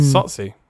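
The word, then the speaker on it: Tsotsi: Following a claim for his previous film, which I cannot pronounce, but I think it's called Sotsi. Yeah, it's a silent T. That's T-S-O-T-S-I. Mm. Sotsi.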